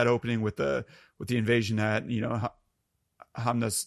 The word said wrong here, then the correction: Hamunaptra: Hamunaptra. – Hamunaptra.